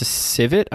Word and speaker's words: civets: It's a civet, I think it's pronounced. – It's a civet cat. – Palm civets. – How do you spell that? – C-I-V-E-T-S.